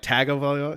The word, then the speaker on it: Tagovailoa: He's like, there's no N in his name. It's because it's spelled like Tagovailoa.